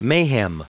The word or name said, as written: mayhem